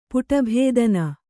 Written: ಪುಟಭೇದನ

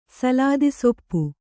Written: ಸಲಾದಿಸೊಪ್ಪು

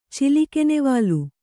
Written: ಚಿಲಿಕೆನೆವಾಲು

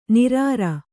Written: ನಿರಾರ

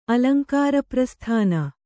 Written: ಅಲಂಕಾರಪ್ರಸ್ಥಾನ

